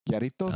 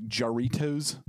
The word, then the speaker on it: Jarritos: J-A-R? Yeah. I think I'm pronouncing it right. It's Mexican. Mm-hmm. Yoritos. – Oh. – Looks like Jarritos, if you want to American it up.